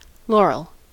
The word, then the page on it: laurel: American English